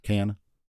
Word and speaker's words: Cannes: C-A-N-N-E-S. But that's Cannes. – The French don't pronounce like the last letters in a lot of things, so I think it's like Cannes or Can.